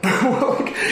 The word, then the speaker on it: Willink: If you hear the guy tell the story on Jenko, is that how you say it? – Willink. Willink. Jocko Willink.